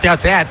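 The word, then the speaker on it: Tete: Por cierto, para confirmar pronunciación, Teate, ¿cómo es? – Yo creo que es Teat. – Yo creo que es Teat.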